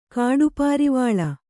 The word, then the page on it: ಕಾಡುಪಾರಿವಾಳ: kāḍu pārivāḷa